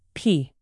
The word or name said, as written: pee